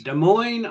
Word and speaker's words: Des Moines: Is it Des Moines or Des Moines? – Des Moines, Iowa. – Des Moines. – The S is silent.